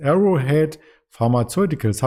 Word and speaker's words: Arrowhead Pharmaceuticals: Und das Unternehmen, was du uns dazu mitgebracht hast aus dem Pharmabereich, ist Arrowhead Pharmaceuticals. Habe ich richtig ausgesprochen, oder? – Genau, richtig.